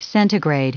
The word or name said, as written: centigrade